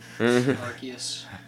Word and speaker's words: Arceus: I would say a hard C. Go back to proper Latin. Arceus.